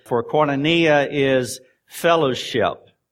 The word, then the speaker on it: koinonia: But the one that I remember is koinonia. Koinonia. That is the Greek word for communication. It is spelled K-O-I-N-O-N-I-A, if you're taking notes.